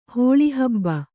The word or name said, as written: ಹುಳಿಹಬ್ಬೆ